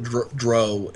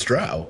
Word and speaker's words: drow: Well, I used to pronounce it dro, and because my brother, who I learned a lot of my gaming from, my brother pronounced it that way until I heard Salvatore say it one day, and I was like, oh, it's drow, okay.